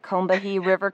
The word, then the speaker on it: Combahee: Well, and some of the examples you give in addition to Soulfire Farm are the Combahee River, am I saying that right? Combahee River Community? – I think you got it.